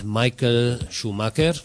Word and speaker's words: Michael Schumacher: Soweit die Taz zum Thema Michael Schumacher oder wie er hier in Spanien heißt, Michael Schumacher. Das ist die richtige Aussprache auf Spanisch.